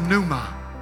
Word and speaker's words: pneuma: In that verse, the word Spirit in its original text is pneuma. Pneuma. You spell it P-N-E-U-M-A. Like pneumonia.